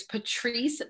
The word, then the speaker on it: Patrease: My handle is Patrice the Coach.